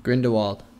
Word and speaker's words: Grindelwald: I, I wish we saw some more of um, was it Grizz- Grisselwald? That's that's how you pronounce his name. Grindelwald.